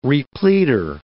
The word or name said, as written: repleader